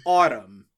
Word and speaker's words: ATUM: So the album is spelled ATUM. I thought it was pronounced Atum, like some kind of Egyptian god, but according to Mr. Billiam, it's pronounced like autumn. Bullshit.